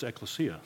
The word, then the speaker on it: ecclesia: And so how do you pronounce it? Because I've always said ecclesia, and he says it's ecclesia.